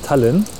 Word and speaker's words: Tallinn: spricht man das Tallinn, so? ja. Tallinn. Die, die, Deutschen sprechen das wohl so aus, die Estländer glaube ich anders.